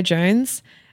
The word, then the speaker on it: Jonze: So my artist name is Jaguar Jones. Jones is spelled J-O-N-Z-E.